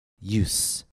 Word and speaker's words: use: Please listen and repeat the following words in which SE after a vowel is pronounced S. House. Mouse. Promise. Use. U-S-E as a noun. Use. Used to. Loose. L-O-O-S-E.